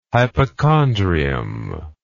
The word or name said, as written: hypochondrium